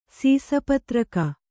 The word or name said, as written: ಸೀಸಪತ್ರಕ